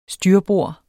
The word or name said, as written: styrbord